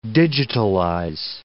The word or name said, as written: digitalize